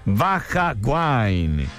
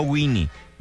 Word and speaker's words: Baja Wine: Baja Wine. Puede conocer Baja Wine a través de su Facebook. Póngale ahí, se escribe Baja Wine. No Winnie, Baja Wine, o sea, Baja Wine.